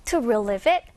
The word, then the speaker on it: relieve: 이렇게 relieve까지 강세를 살리는 것이 아니라 이 relieve란 단어는요. 방금 선생님이 얘기한 것처럼 relieve. Relief 이렇게 이음절의 강세가 있어서요. 이 뒤에 부분을 약하게 상대적으로 발음을 하셔야 돼요.